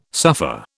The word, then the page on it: suffer: British English